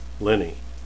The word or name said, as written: lini